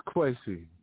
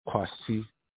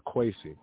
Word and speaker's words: Kwesi: So if you were in Ghana and you're around my people, they would pronounce it Kwasi. But I put my own little flavor onto it and Americanized it, so I go by Kwesi.